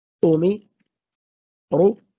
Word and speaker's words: أمروا: الألف بعد واو الجماعة، لو أخذنا مثال مثلا كلمة أمروا، هكذا تقرأ: أو مي أومي رو أوميرو رو أوميرو، والألف التي بعد الواو لا أثر لها في النطق.